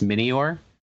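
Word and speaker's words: Minior: No, they're based on meteors. That's why it's actually should be pronounced mini-or.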